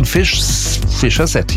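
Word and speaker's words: Fischer-Z: Ganz einfach, die Band war sehr lustig drauf und hat sich gedacht, wir nehmen einen Namen, der so ähnlich wie Fish's Head klingt. Ausgesprochen Fischer Z, ja, also Fishkopf.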